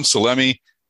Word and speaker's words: Salemi: I am on LinkedIn, Tom Salemi. My last name is spelled S-A-L-E-M-I.